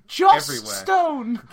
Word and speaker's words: Joss Stone: It's called Joss Stone. – Good, okay. – Rhymes with moss stone, you see.